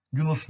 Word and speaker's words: Yunus: ayete gelebiliriz artık Hazreti Yunus'a. Biz Yunus diyoruz ama bunun okunuşu ilk harfi uzun biraz. Yunus şeklinde.